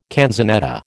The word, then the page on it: canzonetta: American English